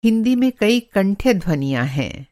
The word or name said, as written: कंठ्यध्वनि